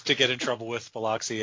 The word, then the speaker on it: Biloxi: It's Biloxi, and they're pronouncing it Bilaski.